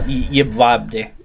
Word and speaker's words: Jedwabne: Yeah, Jedwabne.